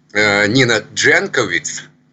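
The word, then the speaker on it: Nina Jankowicz: И, конечно, можно говорить о консерваторах, но, в общем-то, мне кажется, что, да, и сама, конечно, Нина Дженковиц, так американцы произносят ее имя, у нее отец серб, поэтому не знаю, как дома они... ее имя и фамилию произносят.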